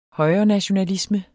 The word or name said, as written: højrenationalisme